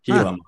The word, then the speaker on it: Gila: It's an H, I think. Gila monster. – Yeah, I say Gila, like with like an H.